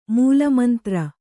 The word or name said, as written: ಮೂಲಮಂತ್ರ